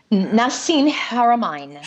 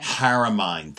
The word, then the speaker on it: Haramein: I know you know the physicist, and I always say his name wrong, but Nassim Haramian? Haramian? – Nassim Haramein. – Haramein. Thank you for that.